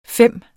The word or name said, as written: FEM